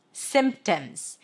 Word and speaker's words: symptoms: Well, there is a P there, but you don't have to pronounce it. So let's talk about how to pronounce this word. Sim. It's an S sound, relaxed I, and then an M. Sim. A silent P. And then tims. Symptoms.